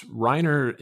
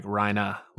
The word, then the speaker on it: Reiner: It's Reiner in the dub, but it's kind of funny. A lot of the times it sounds like they're saying like Reina, like they don't pronounce like the final R. It's like Reina.